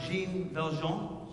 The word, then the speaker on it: Jean Valjean: Now, I never pronounced the guy's name right, but it's Jean Valjean. That's his name.